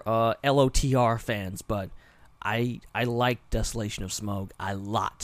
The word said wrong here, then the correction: Smaug: Smog. I keep saying it wrong. Smaug. Smaug. Smaug.